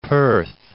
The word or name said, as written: Perth